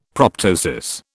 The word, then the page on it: proptosis: British English